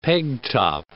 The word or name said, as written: peg-top